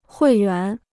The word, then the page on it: 会员: huì yuán